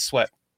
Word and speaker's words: Sweat: So how do you spell Sweet? – Well, I know it, but it can be pronounced differently. – It's W-E-E-T. – I know it, but this is W-E-A-T. I get that. – Oh, I love you, Lynn. It's Sweat. – It's Sweat. Okay, so Sweat.